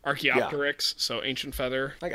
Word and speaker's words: Archaeopteryx: Archaeopteryx. Archaeopteryx. Okay. It's not unreasonable for you to realize it's a silent P.